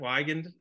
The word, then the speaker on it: Weigand: Trent Wiegand, is that how you pronounce the last name? – Yeah, that's right, Wiegand.